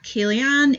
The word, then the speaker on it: Ceilidhann: You can find me on Twitter at Kaylianne. It's spelled C-E-I-L-I-D-H-A-N-N.